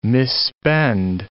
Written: misspend

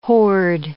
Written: horde

